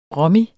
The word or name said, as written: rommy